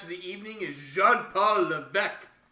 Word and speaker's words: Levesque: They said Levesque, and I'm like, no, it's Levesque. You don't pronounce the S.